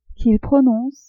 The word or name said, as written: qu'il prononce